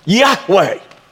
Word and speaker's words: Yahweh: How do you pronounce something like that? YHVH. You know, but you put some vowels in it and it becomes Yahweh.